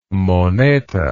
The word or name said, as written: moneta